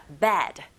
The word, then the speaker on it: bed: 자, bed. 이렇게 발음하시고, 나쁜이란 뜻의 형용사는 bad. Bad 이렇게 발음하시면 됩니다. 철자 다르고 소리도 비슷한 것 같지만 달라요.